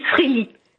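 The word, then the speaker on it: Frelighsburg: Je dois vous dire que moi-même, quand, ben, en fait, même ici, dans la communauté, on s'entend pas sur comment le prononcer. Alors, il y a le Frelisberg, oui. – Frelisberg, oui. Et beaucoup disent Freli, tout simplement. – Ah oui. – On coupe comme ça, oui, c'est plus simple.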